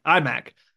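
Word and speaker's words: Aimaq: How do you say his name? I'm sure you know better. – Imak. Okay. Imak actually didn't do much anything against USC.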